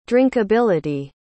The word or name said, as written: drinkability